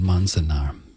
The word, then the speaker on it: Manzanar: And that is the correct pronunciation, Manzanar, apple orchard.